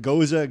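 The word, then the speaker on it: Gose: I am drinking Goza Gone Hopped. It is good pronunciation. – Thank you. I practiced earlier.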